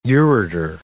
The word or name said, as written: ureter